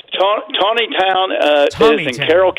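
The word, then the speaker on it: Taneytown: It's like Tawny Town. Okay. – They pronounce it Tawny. It's spelled like Taney.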